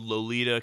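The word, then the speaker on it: Loleta: It was mostly filmed in a town called Lolita, California, not spelled like the book or movie. It's a little different spelling, but it is pronounced like that.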